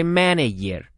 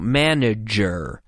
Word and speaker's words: manager: And so when it appears in the middle of a word like manager, you have to be careful not to pronounce it like a Y. We don't say manager. No, manager.